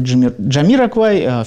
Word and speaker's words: Jamiroquai: что в произношении группы Jamiroquai все-таки именно так ставится ударение.